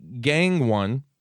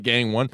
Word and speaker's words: Gangone: Uh, then we had Anthony Gangone it's spelled like Gang One. Perfect. How do you say it? Gangon. Gangon. Gangon.